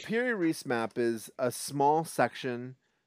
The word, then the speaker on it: Piri Reis: the Rere Reis map, or am I saying that wrong? – The Piri Reis map. – The Piri Reis map, thank you.